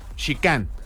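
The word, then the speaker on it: Xican: Esta canción se llama Nueva Tierra y es de un artista llamado Sican o Sican. Se escribe con X, Sican.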